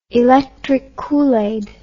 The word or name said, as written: electric koolaid